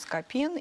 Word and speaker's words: Скопин: Да, ну вот правильное ударение ставит Скопин, и он от Скопы, а не от Скопцов.